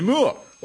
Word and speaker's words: Moore: You see, with a double O, we would say Henry Moore or Roger Moore. But with one O, we'd say Kenneth Moore.